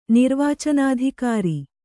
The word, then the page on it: ನಿರ್ವಾಚನಾಧಿಕಾರಿ: nirvācanādhikāri